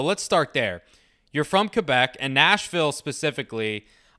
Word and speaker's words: Quebec: It's pronounced Quebec, right? Not Quebec. – Yep. – Okay. – Yeah, good. Quebec. – I didn't want to be a bad American Quebec and be like that, you know? – It depends. I don't really know what's the – I mean, we pronounce it different in French. So Quebec, yeah. Quebec's fine.